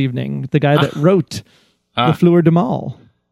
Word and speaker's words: Les Fleurs du Mal: Les Fleurs du Mal, written by Taylor Martin and Kelly Johnson and directed by Lisa Robinson.